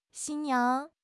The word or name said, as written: 新娘